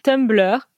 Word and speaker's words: Tumblr: Et alors pour toi Du coup, pour moi qui suis poisson, c'était Tumblr. Je sais même pas si je le prononce correctement. Ouais, c'est ça.